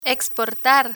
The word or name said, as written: exportar